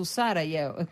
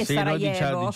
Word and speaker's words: Sarajevo: poi ammiro la tua pronuncia su Sarajevo, perché per noi è Sarajevo, noi mettiamo l'accento dopo, ma probabilmente la tua pronuncia è quella corretta, ovviamente.